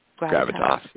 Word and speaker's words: Gravitas: Gravitas? – Gravitas, yeah.